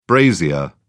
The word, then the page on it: brazier: British English